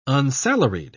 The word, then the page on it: unsalaried: British English